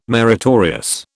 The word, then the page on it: meritorious: British English